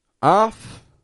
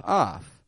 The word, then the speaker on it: of: The first one, O-F-F, off. Most people don't struggle with that one. But the second, of, a lot of people kind of overthink that sound. And they'll pronounce it just how it's spelled, off, off. The trouble with that is that, as you can hear, it's a little different from what I'm saying.